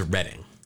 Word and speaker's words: Reading: Now, I'm pretty sure that's Reading, and I don't want to. I mean, we got emails when I mispronounced Dunedin. – Oh, so I read it properly. Reading, Pennsylvania. – I just want to make sure. And maybe you're right, but I'm pretty sure it's Reading.